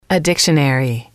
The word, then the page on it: a dictionary: female